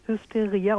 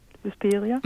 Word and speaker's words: Hysteria: Es wird wirklich so ausgesprochen. Hysteria und nicht Hysteria.